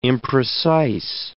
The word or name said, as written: imprecise